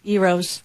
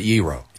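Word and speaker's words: gyro: Petros, Papa George, Euros. – And you pronounce it Euro. – I do. He's from Greece. It's 100% Euro.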